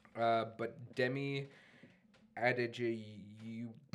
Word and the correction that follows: Adejuyigbe: Okay, well, it's Adejuyigbe. – Whoa. – Yeah, so it's Adejuyigbe. – Scrabble, high score. – Say it one more time. Say it one more time. One final pronunciation. – Okay, wait, let me think this through. Adejuyigbe.